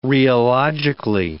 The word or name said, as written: rheologically